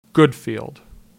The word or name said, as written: Goodfield